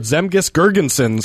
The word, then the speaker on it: Zemgus Girgensons: On a team stacked with young draft picks, 19-year-old rookie forward Zemgus Girgensons. – Good pronunciation. – Thank you.